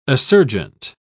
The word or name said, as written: assurgent